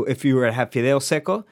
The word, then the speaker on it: fideo seco: Okay, tell us about fideo seco. Did I say that right? – You did say that right.